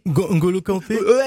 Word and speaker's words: N'Golo Kanté: N'Golo Kanté.